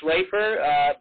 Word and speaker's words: Schlaefer: Jamie, it's Schlaefer, but I give you a lot of points for effort because it's a difficult name.